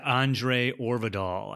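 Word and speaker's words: André Øvredal: Yeah, Andre Orvidal, I believe is the pronunciation of his name.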